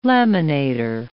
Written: laminator

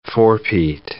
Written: four-peat

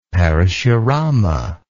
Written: parashurama

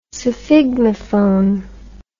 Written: sphygmophone

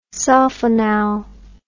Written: sulfonal

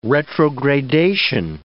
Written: retrogradation

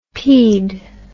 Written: pede

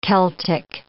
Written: Celtic

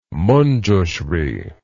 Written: manjusri

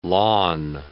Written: lawn

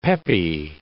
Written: peppy

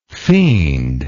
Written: fiendlike

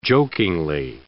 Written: jokingly

